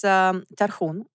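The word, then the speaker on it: tarhun (Тархун): It's tarhun, which is... – Tarhun. – Is that right? – That's very good.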